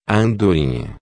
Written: andorinha

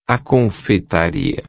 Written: confeitaria

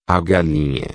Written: galinha

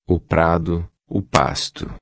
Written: prado pasto

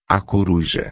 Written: coruja

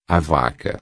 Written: vaca

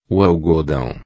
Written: algodao

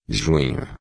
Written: junho

